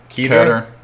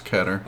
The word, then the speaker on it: Keter: Safe, Euclid, Keter? – Keter. It's Keter.